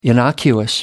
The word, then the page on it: innocuous: American English